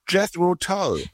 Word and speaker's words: Jethro Tull: Jethro Tull? – Jethro Tull.